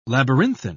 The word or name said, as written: labyrinthine